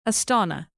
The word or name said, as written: astana